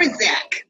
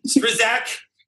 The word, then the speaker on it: Strzok: But Peter Strzok or Strzok or however you say his name, I still don't know. Strzak. – Strzak.